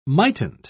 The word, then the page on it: mightn't: British English